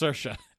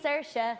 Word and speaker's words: Saoirse: It's sh instead of s instead of e, simple as can be. See, Saoirse.